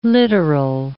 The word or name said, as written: littoral